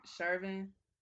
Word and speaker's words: Chauvin: His name Derek Chauvin.